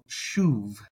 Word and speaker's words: שׁוב: So in today's lesson, we're going to be learning about the Hebrew word that is spelled shein, vav or vow, bet. So shuv.